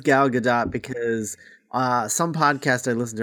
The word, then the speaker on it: Gal Gadot: Yeah, because is it Gal or Gail Gadot? How do you say her first name? – Gal, I say. I don't know. Gal Gadot. – It's Gal Gadot.